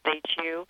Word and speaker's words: HU: It's pronounced H-U. And we sing it, HU.